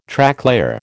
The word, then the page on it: Tracklayer: American English